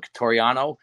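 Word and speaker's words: Quitoriano: His last name is spelled Quit Oriano, so Cotariano.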